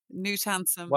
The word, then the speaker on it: Knut Hamsun: I used to go around calling him Canute when I first discovered him, um, but I'm delighted that you've you've you've corrected me there, so I now know in future Knut Hamsun.